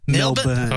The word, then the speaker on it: Melbourne: How do you say it? Is it Melbourne? – Melbourne.